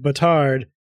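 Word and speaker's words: bâtard: They're the Butarians instead of the Batarians, because the French word batard is, which is pronounced batar, means bastard.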